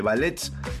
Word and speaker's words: ballets: También espectáculos folclóricos, presentaciones de ballets, la T no se pronuncia, dirían los Simpsons, y la tradicional bailanta chamamesera.